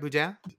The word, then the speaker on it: boudin: Did I say it right, Boudin? – It's Boudin, but yes. – Boudin? Boudin. Oh, did I put the emphasis on the wrong syllable? – Yeah, Boudin?